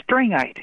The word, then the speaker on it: Strangite: The book title is Children of a Northern Kingdom, subtitled A Story of the Strangite, I think that's pronounced, Mormons in Wisconsin and on Beaver Island, Michigan. Did I pronounce that right? – Stringite is what it's Stringite.